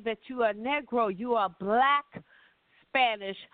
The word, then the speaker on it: negro: Because the word Negro, and that's how they pronounce it, Negro, N-E-G-R-O.